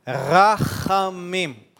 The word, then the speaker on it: Rachamim: Ch, Rachamim, das kommt aus der Kehle.